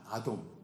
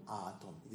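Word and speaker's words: 아돔 (אדום): 붉은 것 붉은 것의 그 발음은 아돔입니다. 아돔, 아돔.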